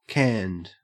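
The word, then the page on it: canned: au Australian English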